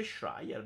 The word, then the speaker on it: Schreier: Che si legge Schreier, bravo Sippo, bravo, che sei migliore di noi.